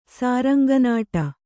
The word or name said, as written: ಸಾರಂಗನಾಟ